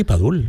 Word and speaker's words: Padul: Padul, sí, no sé si lo digo bien, perdonadme. – Sí, sí, Padul, en Padul, Granada.